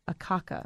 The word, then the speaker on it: Akaka: You held senior legislative positions in the United States Senate for Senator Harris Wofford and Senator Daniel Akaka.